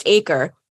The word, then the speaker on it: AQRE: So the name of Supima's newly launched Acre traceability solution, it's spelled A-Q-R-E, but pronounced Acre, it stands for authenticity, quality, and responsibility.